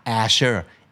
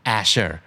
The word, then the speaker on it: azure: Asher A Z U R E Asher